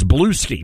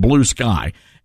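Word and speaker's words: Bluesky: At first I thought it was Blueski, but I think it really is pronounced Blue Sky.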